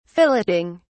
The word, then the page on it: filleting: British English